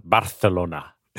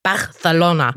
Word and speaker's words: Barcelona: Aber ich glaube, mit spanischem äh, Akzent sagt man Barcelona. Barcelona.